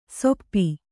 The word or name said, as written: ಸೊಪ್ಪಿ